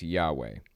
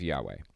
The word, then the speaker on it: Yahweh: It's usually thought, though, that when pronounced, it was Yahweh.